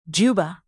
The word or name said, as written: juba